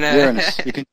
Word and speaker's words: Uranus: And, Uranus. You can just use the British pronunciation and say Uranus.